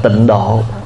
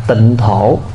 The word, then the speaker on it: tịnh thổ: Ví dụ như khi chúng ta viết chữ tịnh độ thì chúng ta viết ra chữ Hán là tịnh thổ, nhưng mà người Việt mình khi đọc cái chữ đó thì đọc là tịnh độ, nhưng mà đúng chữ thì là đọc là tịnh thổ, tại cái chữ đó là chữ thổ chứ không phải chữ độ.